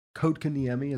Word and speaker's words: Kotkaniemi: So they played Montreal, Kotkaniemi, as we found out, as it's pronounced.